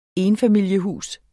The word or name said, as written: ENFAMILIESHUS